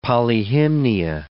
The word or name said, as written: Polyhymnia